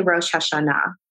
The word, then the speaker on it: Rosh Hashanah: So Rosh Hashanah, how do you pronounce that again? – You can say Rosh Hashanah.